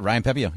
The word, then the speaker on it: Pepiot: Ryan Pepiot, right? That's how you say his name? – Yeah, Ryan Pepiot, yeah.